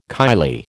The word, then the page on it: kylie: American English